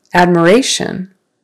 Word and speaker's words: admiration: Admiration. Admiration. Third syllable stress.